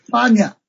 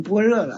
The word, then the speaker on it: 般若: 哦，那个若就是那个用一下就念，就这个就那他古代一念念就是巴尼亚，这么念的。这就现在念成般若了。